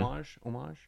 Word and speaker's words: homage: Homage? – It is homage, yes. You're saying it correctly.